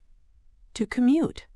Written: to commute

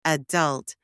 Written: adult

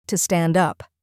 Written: to stand up

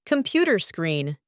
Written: computer screen

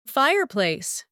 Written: fireplace

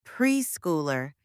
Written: preschooler

